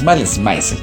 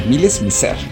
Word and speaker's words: Malice Mizer: Y hoy damos inicio a una que consideramos que es nuestra favorita, no es Malice Mizer, es Malice Mizer. – Malice Mizer, pero es como algo así como la pronunciación en francés.